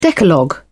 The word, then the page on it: decalogue: British English